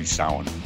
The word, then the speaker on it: Samhain: Happy champagne. – Happy Samhain. – There you go. Yeah. – At least you corrected the pronunciation.